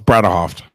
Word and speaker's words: Bredehoft: Close. It's Breedhoff. – Breedhoff. Breedhoff. So there's actually there that syllable in the middle.